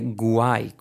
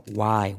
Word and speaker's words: why: And why, nada de why con G, que suele ser también un error común, sino why. Guau, guau, guau, guau. Why, cómo mola. Why. Es lo que te comentamos aquí, cuidado con la pronunciación.